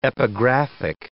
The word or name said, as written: epigraphic